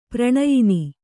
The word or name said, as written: ಪ್ರಣಯಿನಿ